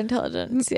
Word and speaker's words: Intelligentsia: No, okay, we were at Intelligentsia, or is that how you pronounce it? – We were at Intelligentsia.